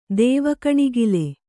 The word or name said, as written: ದೇವಗಣಿಗಲು